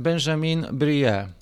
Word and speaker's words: Benjamin Brie: On się nazywa, żebyśmy mieli jasność, e, tutaj się przygotowałem, Benjamin Brie. Benjamin Brie. Benjamin Brie, jeszcze raz. Tak, tak nam powiedziała pani, więc mamy jasność, jak się to wymawia.